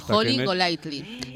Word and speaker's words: Holly Golightly: Holly Golightly es la protagonista.